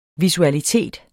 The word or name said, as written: visualitet